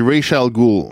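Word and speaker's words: Ra's al Ghul: Comment tu l'as prononcé? – C'est Rachel Gould. – OK, je savais pas comment le prononcer. Donc, Liam Neeson, il joue... – Ouais, c'est écrit bizarre aussi. – Ouais, c'est Race, R-A-S, Al Ghul.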